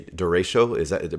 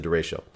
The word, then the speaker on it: derecho: Just before the show, you were telling me that you recently went through something that I'd even never heard of, which is a derecho. Am I pronouncing that correctly? – Yep, I think you're pronouncing it correctly.